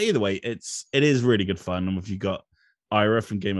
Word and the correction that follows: Arya: of Thrones, Ira Stark, is that the right way to pronounce it? – Arya, yeah.